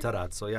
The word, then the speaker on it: Matarazzo: Gaetan Matarazzo, did I say it right? – Matarazzo, yeah.